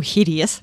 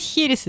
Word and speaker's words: херес: По-нашему херес, потому что ставится ударение в этом случае. Ну, последний слог, и я теперь боюсь, уже не смогу говорить херес, извините, поэтому буду говорить херес.